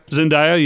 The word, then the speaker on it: Zendaya: Is that how you say her name? – Zendaya, yeah.